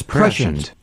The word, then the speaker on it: prescient: Well, he dropped prescient. A syllable on that last one. He doesn't know. – So it's prescient.